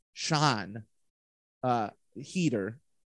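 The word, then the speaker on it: Sian Heder: And then the last one is, I have the director of Coda, who I believe's name is pronounced Sean Heater.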